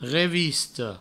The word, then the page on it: revista: revisto; Provençal